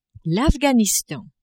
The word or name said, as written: Afghanistan